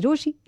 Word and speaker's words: Rochie: मेरा नाम है मिर्ची रोशी, नाम की स्पेलिंग है RochiE, so do whatever you have to do.